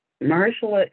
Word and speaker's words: Mahershala: And Mahershala, it's very, for me, it's easy to say his name because he looks like a Hershey candy bar. so I see his name and it reads like Hershey's to me. So Mahershala, yeah.